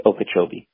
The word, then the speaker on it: Okeechobee: And now I was on the northern tip of Lake Okeechobee.